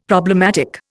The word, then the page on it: problematic: Indian